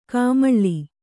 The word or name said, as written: ಕಮ್ಮಾಳ